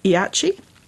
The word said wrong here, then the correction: Ishii: Ishii. Sorry, I said that wrong. Takeo Ishii.